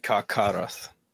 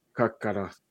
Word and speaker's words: Carcharoth: I love this that Karchadoth or Kark, I don't know if CH is a K sound. – It's pronounced as like Karkaroth. – Karkaroth.